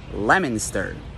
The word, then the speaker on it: Leominster: It also has some silent letters, but none of the ones you're expecting. Leominster.